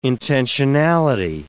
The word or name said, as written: intensionality